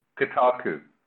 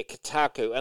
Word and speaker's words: Kotaku: How do you pronounce the name of the website? It's like Kotaku or something? K-O... – Kotaku. – Yeah, Kotaku. And they said, the Xbox One is dead, and we do not mourn it or something.